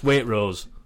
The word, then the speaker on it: Waitrose: Wait. Rose. Waitrose. – You've just explained that the exact same way that I say it. No, because you're not pronouncing the T.